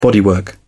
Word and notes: bodywork: British English

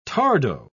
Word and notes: tardo: British English

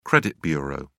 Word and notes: credit bureau: British English

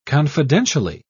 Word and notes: confidentially: British English

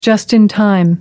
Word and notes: just in time: American English

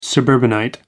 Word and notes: suburbanite: American English

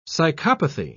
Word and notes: psychopathy: British English

